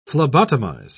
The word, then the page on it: phlebotomize: British English